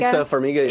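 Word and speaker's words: Taissa Farmiga: Anyway, so it's already confirmed Sarah Paulson, Evan Peters, and Tysa, how do you say her name? Tysa Farmiga? – Tysa Farmiga, yeah.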